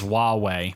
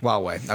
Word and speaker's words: Huawei: And its encouraged pronunciation is Huawei. – Huawei, okay.